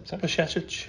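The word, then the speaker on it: Bajčetić: Bashetic. – There you go. That's how you pronounce it. – So just put a sh in the middle of it.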